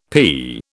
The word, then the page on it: p: British English